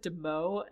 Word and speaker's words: Demoux: Also, I'm really upset because, so I speak French, and so I should have pronounced Demoux's name correctly, and I definitely said Demoux and Renault for the longest time, but it should definitely be Demoux.